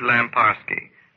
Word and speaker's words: Lamparski: Write to me, Richard Lamparski. That's spelled L-A-M-P-A-R-S-K-I. At W-B-A-I-F-M.